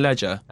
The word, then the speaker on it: Legere: Which is pronounced Ledger, apparently. Not the fancy French way that we've been saying it for ages.